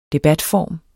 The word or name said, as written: debatform